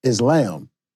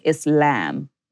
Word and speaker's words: Islam: So it's pronounced Islam. – Islam. Well, yeah, but you know, we have different pronunciations, but if you're doing in Arabic, it would be like Islam.